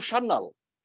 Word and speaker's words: Sanyal: Natürlich wird mein Nachname auch nicht so ausgesprochen, wie ich ihn mein Leben lang ausgesprochen habe. Also Sanyal. – You are Mitu Shannal.